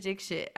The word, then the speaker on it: Dixit: I know someone who I think their given name is someone, and it's spelled D-I-X-I-T. And I was like, oh, Dixit. And they were like, no, it's actually Dixit.